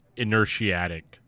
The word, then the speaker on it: Inertiatic: I got somewhere in the middle of like Intertiactic ESP. Hey guys. The word is pronounced inertiatic, not whatever I just said.